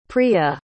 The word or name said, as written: priya